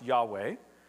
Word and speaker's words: Yahweh: And so in ancient Hebrew, it was YHWH, likely pronounced Yahweh. And so here, when you see L-O-R-D in capitals, and I checked the Hebrew just to make sure I wasn't teaching falsely this morning.